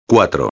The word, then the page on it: 4: espanhol